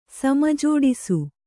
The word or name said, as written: ಸಮಜೋಡಿಸು